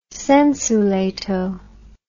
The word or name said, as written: sensu lato